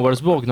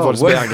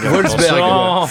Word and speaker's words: Wolfsberg: Non, Wolfsburg, non. – Non, Wolfsberg. – Wolfsberg. C'est pas le même. – Wolfsberg.